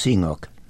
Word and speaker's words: CNOOC: First of all, tell us how to pronounce that. – It's generally pronounced CNOOC.